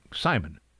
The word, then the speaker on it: CIMON: They shorten that to C-I-M-O-N, presumably pronounced like Simon.